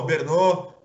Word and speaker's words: Brno: Brno. Muito bom, bela pronúncia do tcheco.